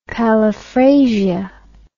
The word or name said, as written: paliphrasia